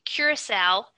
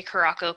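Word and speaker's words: curaçao: A half an ounce of blue curacao, which I guess is how you pronounce it. I always say Caraco. People tell me I'm wrong.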